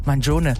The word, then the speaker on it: Mangione: Und jetzt, hilf mir nochmal aus, wie spricht man seinen Nachnamen? – Der wird Mangione. Ja, Mangione.